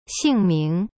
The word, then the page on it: 姓名: xing ming